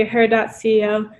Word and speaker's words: her.CEO: Exactly, her.CEO.